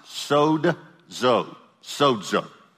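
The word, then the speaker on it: sozo, sozo (σώζω): It's pronounced sozo, sozo. Now, if you see it written in English form, it'll be written S-O-Z-O. And you'll hear people pronounce it sozo, but it's just not the correct pronunciation.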